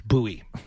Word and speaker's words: Bowie: B-O-W-Y-E. – Yeah, it's not like David Bowie. It's Bowie. They pronounce it Bowie down there.